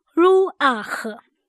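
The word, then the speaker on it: ruach (רוח): 我们现在来看林这个字，林，它的希伯来文读音是 ruach， ruach。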